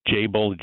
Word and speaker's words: Jabil: I think it's pronounced Jabil. It's Jabil, Jabil, J-A-B-I-L is this, the spelling.